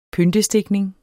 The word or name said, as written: pyntestikning